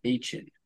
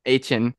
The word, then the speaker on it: Achane: I forgot what his name was, but Devin Ashawn, Ashan, I don't know. – Achen. – Achan. – That is how you say it.